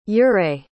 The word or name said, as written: ouray